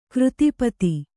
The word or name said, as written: ಕೃತಿಪತಿ